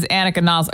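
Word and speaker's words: Annika Nilles: And how do you pronounce her name anyway? It is Annika Niles. According to Alex Lifeson, if you hear Annika pronounce her own name, well, she's German, and it comes out kind of like... So it's hard to grasp. But anyway, Alex Lifeson said Annika Niles, and so that's what we're going with.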